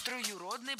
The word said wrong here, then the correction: троюродный: брат. Это неверно. Правильно? Троюродный. Ударение ставим на гласную «ю». Брат троюродный. И сестра, кстати, тоже троюродная.